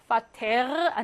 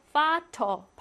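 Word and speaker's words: Vater: 어 단어의 맨 끝에 이렇게 있을 때 파테르 아니고 파터 발음하고요.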